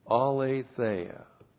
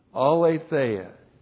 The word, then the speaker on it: aletheia: Aletheia. A-L, long E-T-H-E-I-A. Aletheia.